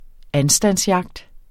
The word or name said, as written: anstandsjagt